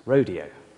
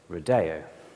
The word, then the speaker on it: Rodeo: very familiar with Aaron Copland's Rodeo, or some might say Rodeo.